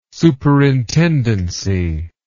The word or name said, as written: superintendency